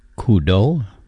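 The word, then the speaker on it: Coudoux: I'm pronouncing that correctly. It's C-O-U-D-O-U-X. Coudeau is how I would say it.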